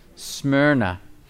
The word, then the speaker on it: Smyrna: Smyrna. And Smyrna is spelled S-M-Y-R-N-A. Sounds like what? Myrrh.